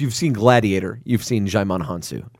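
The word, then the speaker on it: Djimon Hounsou: Korath, played by Jaimon Hansu.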